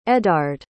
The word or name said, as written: eddard